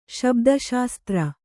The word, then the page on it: ಶಬ್ದಶಾಸ್ತ್ರ: Kannada